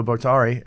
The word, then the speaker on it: Bottary: I mean, What Anyone Can Do by Leo Bottary. And I'm saying that right? Is it Bottary or Bottary? – Yeah. No, you got it.